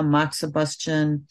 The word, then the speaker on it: moxibustion: Accessory techniques are like, massage, cupping, gua sha, moxibustion, more like manual techniques, and then diet.